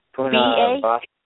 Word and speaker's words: Punarvasu: You spell it P-U-N-A-R. Punar, then B-A-S-U. Punar Vastu. P-U-N-A-R-V-A-S-U.